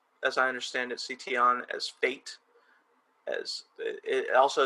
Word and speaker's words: Tian: Sorry if I mispronounced that. – No, you're right. It's Tian.